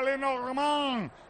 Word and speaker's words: Lenormand: Va la bola para Lenormand. ¿Se dice así o no, Melchor? – Qué bien dicho. – Totalmente. Cada día haces mejor el francés.